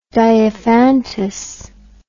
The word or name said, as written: diophantus